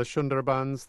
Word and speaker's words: Sundarbans: And I'm thinking of your trip through, am I pronouncing it right, the Sundarbans? – Yeah, the Sundarbans, the coastal forest.